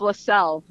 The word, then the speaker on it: Leczel: So that's spelled L-E-C-Z-E-L. Good luck. – Yeah. Say it again. L-E-what? – L-E-C-Z-E-L. – Is it Lexel? – Close. It's LaSalle.